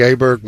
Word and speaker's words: Åberg: Oh, yeah, he's the one who I don't say his name right because I read it and I'm not a big golf fan and I didn't realize it's Åberg, but it doesn't look like that.